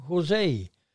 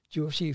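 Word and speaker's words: José: And we called him Josie because, first of all, we called him Jose. – Yeah. – Because we thought, you know, the spelling was as the Spaniards pronounce Jose. But he said, no. In Portugal, it's Josie.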